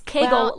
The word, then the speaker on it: Kegel: It's Kegel like bagel. Kegel.